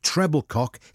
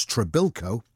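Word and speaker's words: Trebilcock: Mike Treblecock, his name mispronounced by television commentator Ken Wolstenholme as Trebilco,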